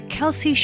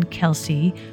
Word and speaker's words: Kelce: Whether Kelce should be pronounced Kells or Kelce has been a topic of discussion even in his own family. Both Travis and his brother Jason Kelce pronounced their last name Kelce on an episode of their New Heights podcast.